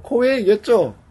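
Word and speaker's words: KOEI: 코에이겠죠. 코에이. – K-O-E-I니까. 아, 코웨이 일본에서는 코웨이라고 하는지 모르겠어요.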